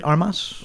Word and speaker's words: Ana de Armas: A couple of fans of actress Ana de Armas. Is that how you say it? Armas? – Yeah.